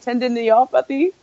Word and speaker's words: tendinopathy: Like, how do you pronounce it? Tendinopathy?